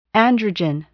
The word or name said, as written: androgen